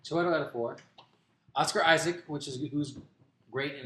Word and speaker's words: Chiwetel Ejiofor: You have Bruce Dern, Chiwetel Ejiofor. – Wait, how do you say that again? – Chiwetel Ejiofor.